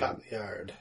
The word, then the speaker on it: Cotillard: Movie stars Christian Bale, Michael Caine, Gary Oldman, Anne Hathaway, Tom Hardy, Marion Cotillard? I'm not sure how to pronounce her name. – Cotillard. – Cotillard? Uh-huh. Sure.